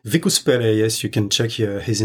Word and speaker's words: Vicus Pelle: It's spelled Vicuspelle, right? – Exactly. – One C, right? – One C, two L.